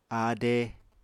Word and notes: áade: apatani

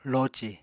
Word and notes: loci: Apatani

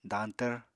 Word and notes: dañtvr: apatani

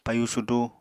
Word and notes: payu sudu: Apatani

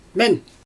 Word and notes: men: Xyzyl

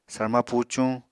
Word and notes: sarma pucuñ: Apatani

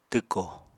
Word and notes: twko: apatani

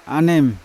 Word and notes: anem: Yawa

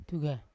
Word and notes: tugai: Yawa

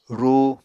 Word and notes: rúu: Apatani

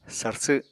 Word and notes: sarsw: apatani

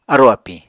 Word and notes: arò-apiñ: Apatani

